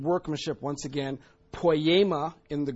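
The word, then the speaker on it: poiema (ποίημα): G4161 in the Greek, it's pronounced poiema, thank you, poiema.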